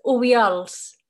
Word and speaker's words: Obiols: It's Obiols.